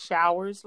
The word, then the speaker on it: Showers: And my name is spelled K-A-M. And my last name is Showers, like the rain showers, S-H-O-W-E-R-S. You can do a search there on Facebook. And my Instagram is Showers, S-H-O-W-E-R-S.